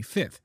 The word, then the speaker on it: fifth: The one that I told you, which is both with the F's are pronounced, and one in which the second F is not pronounced. So we say the first F, but we don't say the second F. We say fifth.